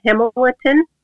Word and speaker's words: Hemolytin: And he uses another one called Hemolytin. In English, it's pronounced like that.